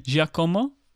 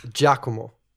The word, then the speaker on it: Giacomo: Giacomo? – No, it's Giacomo.